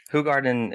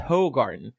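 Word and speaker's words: Hoegaarden: Yeah, it's spelled H-O-E, garden, so you assume it's ho, like Laura's a ho. But it's actually right. Who garden, like who is Laura? – I mean, it used to be...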